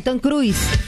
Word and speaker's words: Tom Cruise: ¿Cómo se dice? ¿Tom Cruise o cómo? – Ay, Tom Cruise.